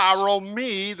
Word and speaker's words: Arami: Arami, that's how it's pronounced in Hebrew.